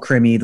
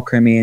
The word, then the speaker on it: Crimi: And you can just Google my name, John Paul Krimi. The last name is spelled C-R-I-M-I. John Paul is spelled J-O-N-P-A-U-L. John Paul Krimi.